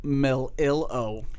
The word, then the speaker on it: Melillo: Today, we have the super special, most amazing man, Mr. Nick Melillo.